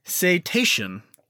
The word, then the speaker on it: cetacean: Oh, and I do have to say that for the last 2 weeks, I've been saying whales and dolphins are cetaceas. Oh, Dave, I'm an idiot, and I was completely wrong in how to say it. – Oh. – It's actually pronounced cetacean. I wasn't even closer. – Good job. – So now it's cetacean.